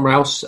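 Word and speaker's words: Rous: Is it Rouse? There are problems with some of the early sources, aren't there? – Yeah, and so, yeah, correct pronunciation. John Rouse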